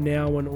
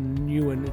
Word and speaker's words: Nouwen: Um, so Henri Nouwen, it's, it's it's spelled Henry with an I, so it's H E N R I and his last name is either pronounced Nowen or Newen.